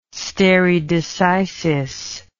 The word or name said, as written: stare decisis